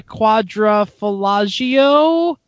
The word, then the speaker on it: Quadrifoglio: Drivers long-term Giulia Quadrifoglio pronunciation check. – Quadrifoglio, yes. – Quadrifoglio. A 4x blowjob had a whiny differential at 10,000 miles.